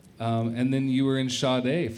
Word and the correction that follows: Shade: for a little while. Am I pronouncing that right? – I had a band called Shade. – Shade.